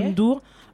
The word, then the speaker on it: Youssou N'Dour: Alors pour revenir sur ce que tu disais, avant qu'on enchaîne sur euh, un morceau de Youssou N'Dour, je prononce bien, Youssou N'Dour,